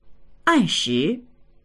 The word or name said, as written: an4shi2